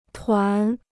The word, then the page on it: 团: tuán